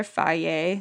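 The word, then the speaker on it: Fage: I prefer faye.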